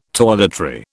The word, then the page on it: toiletry: British English